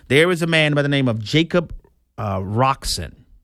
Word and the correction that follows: Coxon: Did I say his name right? Let me make sure I got it. Coxon. I'm sorry. Jacob Coxon is his name.